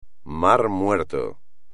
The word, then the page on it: mar Muerto: Spanish